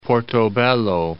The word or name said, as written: Portobelo